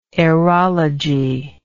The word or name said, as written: aerology